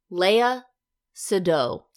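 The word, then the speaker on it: Léa Seydoux: look up her name so I say it correctly. Leia Sado.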